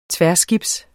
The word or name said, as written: tværskibs